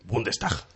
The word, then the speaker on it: Bundestag: Bueno, en el 94 renueva su escaño en el Bundestag, si alguien lo sabe pronunciar mejor. – Bundestag. – Muy bien.